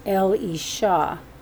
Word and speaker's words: Elisha: Elisha is L. E. Shaw.